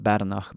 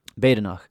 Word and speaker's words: Badenoch: It says he and he Robert the Bruce encouraged other clans to attack the Comyns, especially in their homeland, Badenoch which I had to look up the pronunciation there. I would have just called it Badenoch, but Badenoch was how I found it on YouTube.